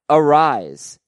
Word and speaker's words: Arraez: He pronounces it Arise, no S sound on the end like Luis Arias.